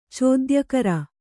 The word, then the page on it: ಚೋದ್ಯಕರ: cōdyakara